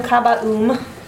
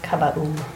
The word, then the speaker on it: Kaba'oom: I am Kabaum, which if you want to say it quickly, it sounds like kaboom, and I'm fine with it. – Nice. – Spell it for me? KABA'OOM. Kabaum.